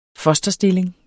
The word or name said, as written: fosterstilling